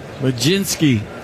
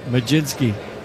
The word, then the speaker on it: Mlodzinski: Majinski. – How do you spell that? – M-L-O-D-Z-I-N-S-K-I. Majinski. Country of origin? – One, and it's a called strike two.